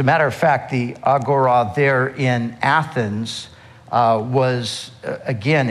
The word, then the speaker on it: agora: We say agora, but the proper pronunciation is agora.